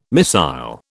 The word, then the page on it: missile: British English